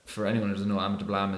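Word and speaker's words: Ama Dablam: So you mentioned, now I'm going to probably butcher this name, but Ama de Blam. – That's right. – Ama de Blam, I'm impressed myself.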